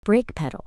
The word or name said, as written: brake pedal